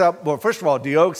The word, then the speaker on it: Doeg: I always want to say his name's Dog, but it's Doeg, Doeg, D-O-E-G.